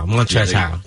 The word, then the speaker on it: Montrezl Harrell: Montrezl Harrell.